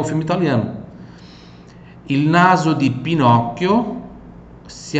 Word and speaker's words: Pinocchio: Pinocchio, mais uma vez o CHI aqui com som de chi.